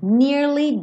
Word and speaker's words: nearly: Nearly done. Tem que ter esse Y no som do nearly. tá? É uma palavra de duas sílabas, nearly.